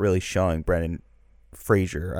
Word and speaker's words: Fraser: I should say, how do you say it properly? – Fraser. – I can't. – Fraser. – Fraser, that's what it is. Yeah. I got to just imagine a Z there every time.